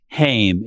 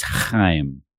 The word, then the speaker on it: Chaim: It's not Sukkot. It's not haim. It's chaim.